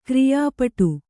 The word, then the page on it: ಕ್ರಯಪಟ್ಟಿ: krayapaṭṭi